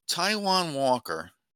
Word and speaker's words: Taijuan Walker: Afterward, he said he was worried about Taijuan Walker. Am I saying his name right? – Yeah, that's right.